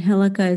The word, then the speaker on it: Hillukka: So my name is Megan, M-E-G-A-N, and Hilika is H-I-L-L-U-K-K-A.